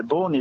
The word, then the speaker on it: Beaune: Well, Beaune, you said it very well. Beaune is one of the most amazing French cities in Burgundy.